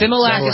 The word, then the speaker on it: Similac: Is it Similac? I've seen this. I don't know how to pronounce Similac.